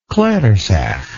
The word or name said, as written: clairseach